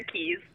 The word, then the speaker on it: Keas: Sarah Keas.